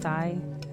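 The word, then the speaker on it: Thay: Just Ty, that's me.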